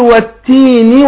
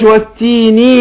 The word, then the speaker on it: والتين: فنقول والتين والزيتون، هنا أبين الكسر اللي في النون بس ما بالغش، لا أبالغ في كسر النون حتى تنقلب إيه ياء. ينفعش والتيني والزيتون يبقى بات ياء، لا أقول والتيني والزيتون، والتيني والزيتون حركة وليست حرف.